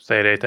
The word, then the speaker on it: Seireite: But they also needed it to break through the barrier into the Seireite. I think that's how you pronounce it. – Seireite, yes.